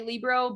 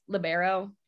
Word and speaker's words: libero: Ooh, that's an old man's way to say it. – Oh wow. Got him. I told you. – I'm kidding. But honestly, like the older generation does say libero, but we typically pronounce it libero.